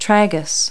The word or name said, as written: tragus